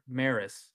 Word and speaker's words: Maris: This player and Roger Maris, I think that's how you say that.